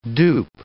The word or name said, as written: dupe